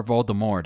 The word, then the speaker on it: Voldemort: Or Voldemort, depending on your pronunciation. – True. – If you have a silent T or emphasis on the T.